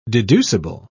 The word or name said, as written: deducible